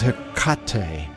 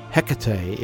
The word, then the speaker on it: Hecate: She's Hecate, if you're Greek, or Hecate, if you speak English. As we have no wish to insult the goddess, we'll use the Greek pronunciation.